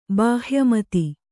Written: ಬಾಹ್ಯಮತಿ

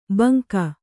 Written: ಭುಂಗಾ

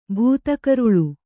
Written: ಭೂತಕರುಳು

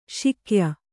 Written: ಶಾಕ್ಯ

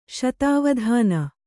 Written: ಶತಾವಧಾನಿ

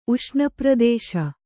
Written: ಉಷ್ಣಪ್ರದೇಶ

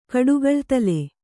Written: ಕಡುಗಳ್ತಲೆ

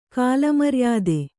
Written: ಕಾಲಮರ್ಯಾದೆ

